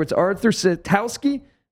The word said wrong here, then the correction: Sitkowski: Art Sitkowski.